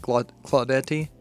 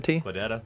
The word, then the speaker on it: Claudette: Claudette. That's how I would pronounce it. – Could be like Claudetti. – Claudetta.